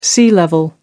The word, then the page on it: sea-level: American English